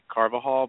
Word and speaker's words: Carvajal: I heard you pronounce my name earlier, Carvajal, but it's actually Carvajal, but it's really kind of a hub.